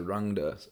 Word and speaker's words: Rangda: Okay, so Rangda. Okay, I've been pronouncing it incorrectly for a couple of years. – That's okay. Hey, all of us, Chris and I in the band also were, we were named by Rick Bishop, and it took us a few months of Rick correcting our pronunciation as well. Say, it's Rangda.